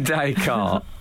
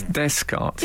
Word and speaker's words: Descartes: René Descartes, the philosopher, was described by Al as Descartes instead of Descartes. It's a simple mispronunciation.